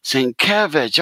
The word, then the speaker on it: Sienkiewicz: and on the big letters above this booth it said Sin in small letters, and it had Kev in great big letters. Itch on the end. And I went, oh, that's how you pronounce it. Sienkiewicz.